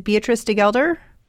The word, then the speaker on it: Beatrice de Gelder: Is it Beatrice de Gelder? – That's perfect.